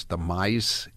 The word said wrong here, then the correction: Mises: Institute, M-I-S-E-S. Am I pronouncing that correctly, Tom? Probably not, right? – It's Mises, Howie.